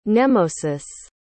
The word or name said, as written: nemausus